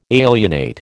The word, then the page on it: alienate: American English